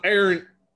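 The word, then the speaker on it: Arent: Hey, man, it's loyal son of Aaron, son of Halen.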